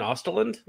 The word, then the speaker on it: Östlund: Well, yeah, and it's kind of a weird thing because, like, with Triangle of Sadness, it feels like that director, it was Ruben Östlund. Is that how you pronounce his name? – Something like that.